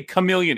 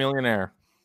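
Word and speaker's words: Chamillionaire: Well, he pronounced it chameleon. He couldn't even say chameleon. He couldn't even say it. Right? – Chameleonaire.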